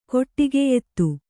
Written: ಕೊಟ್ಟಿಗೆಯೆತ್ತು

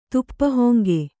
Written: ತುಪ್ಪಹೊಂಗೆ

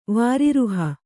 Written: ವಾರಿರುಹ

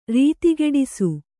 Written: ರೀತಿಗೆಡಿಸು

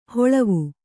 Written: ಹೊಳವು

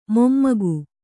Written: ಮೊಮ್ಮಗ

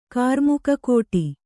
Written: ಕಾರ್ಮುಕಕೋಟಿ

